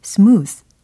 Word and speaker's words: smooth: 어, 특히 발음상으로 smooth 이 부분 예, 끝소리에 조금 더 신경을 써주시면 좋겠어요. 스와 드 요거 사이에 어느 부분에 그 발음이 되거든요. 스와 드의 중간 지점. 예. 그래서 smooth, smooth, smooth 이렇게 해주시면 되겠습니다.